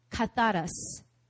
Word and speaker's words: katharos: You know, that word pure, in Greek, it's katharos.